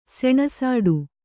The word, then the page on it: ಸೆಣಸಾಟ: seṇasāṭa